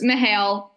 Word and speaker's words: Mihail: Mihail, either Mihail. Okay, so it's not a double syllable, it's Mihail.